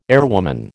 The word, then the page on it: airwoman: American English